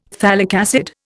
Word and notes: phthalic acid: Indian

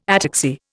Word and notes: ataxy: Indian

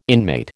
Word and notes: inmate: American English

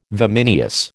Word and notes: vimineous: American English

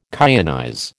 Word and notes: kyanize: American English

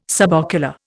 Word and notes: subocular: Indian

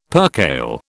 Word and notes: percale: British English

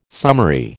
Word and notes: summary: American English

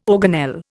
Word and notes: organelle: Indian

